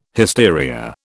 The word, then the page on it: hysteria: British English